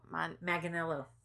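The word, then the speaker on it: Manganiello: How do you pronounce his last name? Mangianello? – Manganello. – Manganello. – Say it was Manganello. That's what we just, on the clip we just watched, the announcer. But I think maybe every announcer pronounces it differently.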